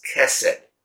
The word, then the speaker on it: Chesed: The theme of this book is a Hebrew word that is kind of, I give you the word, we pronounce it as Chesed, but it's spelled C-H-E-S-E-D.